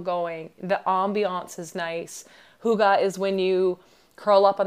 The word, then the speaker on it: hygge: It's spelled H-Y-G-G-E. You'd probably want to pronounce it hygge. It's pronounced hygge.